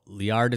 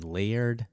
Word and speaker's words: Liard: Liard is how I pronounce it. Watching some videos online, I heard Layard, I heard Liard, I heard lots of different things. Liard is kind of how I say it. So that's how I'm going to say it.